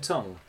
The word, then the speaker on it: Tunng: T-U-N-N-G. Who I've always thought is pronounced Tung.